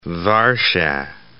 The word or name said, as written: Warschau